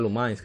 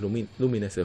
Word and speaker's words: Lumines: Eh, algunos de los juegos de lanzamiento, eh, Apescape on the Loose, Darkstalker Chronicle The Chaos Tower, Dynasty Warriors, Lumines, yo creía que era Lumines que se pronunciaba, pero es Lumines, el juego de, como de sí, Clásico Lumines, sí.